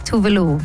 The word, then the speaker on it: Tove Lo: The exact is Tove Lo. – Tove Lo.